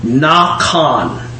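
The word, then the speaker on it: Nakan (נקם): Nakan. Like N-A-W-K-A-W-N is how phonetically how it sounds.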